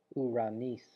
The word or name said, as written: ouranis